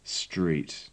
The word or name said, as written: street